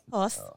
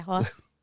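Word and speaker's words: Hoz: We have Sabrina de la, how do you say that? H-O-Z. I don't know. – Hoss. – Hoss? – Yeah, Hoss.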